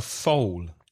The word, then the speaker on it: foal: Baby horse is a foal, F-O-A-L. The vowel pronunciation is O, foal.